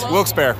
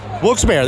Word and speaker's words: Wilkes-Barre: How do you say the name of this place? Wilkes Bear. Wilkes-Barre. That's two for Wilkes-Barre.